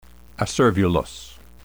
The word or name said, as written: acervulus